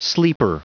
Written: sleeper

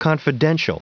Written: confidential

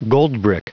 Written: goldbrick

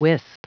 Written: wisp

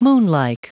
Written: moonlike

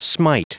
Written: smite

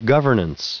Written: governance

